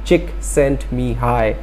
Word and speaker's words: Csikszentmihalyi: Now, Mihaly Csikszentmihalyi, well, the spelling is really bad, so if you want to pronounce it as Csikszentmihalyi.